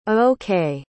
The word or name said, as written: A-OK